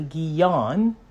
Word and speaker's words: Giv'on: And when the inhabitants inhabitants of Givon, or in some places is actually Gion because there is no V sound, heard from beginning to end what Yahusha had done, to Jericho and to Ai, which is spelled AI.